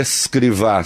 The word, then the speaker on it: Escrivá: O fundador do Opus Dei é um santo da Igreja Católica, São José Maria Crivá. Acha a foto dele aí. É, São José Maria Escrivá, se escreve.